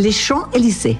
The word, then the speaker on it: Les Champs-Élysées: ¿Tú pronuncias bien francés? – Oui. – Champs-Élysées. – Les Champs-Élysées. – Eso. Perfecto.